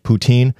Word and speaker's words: poutine: Or as some people who don't know that French Canadian pronunciation, poutine, which is fries with brown gravy and cheese curds.